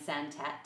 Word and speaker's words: Santat: It was The Adventures of Beagle, The Unimaginary Friend by Dan Santat. Santat? Am I saying that right? – Sounds good.